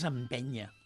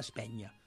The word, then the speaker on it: empenya: O sigui, en principi, en general, és empenya. Empenya, que en alguns llocs se pronuncia espenya, no? O espenya, també en ve tancada, no?